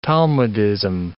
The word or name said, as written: talmudism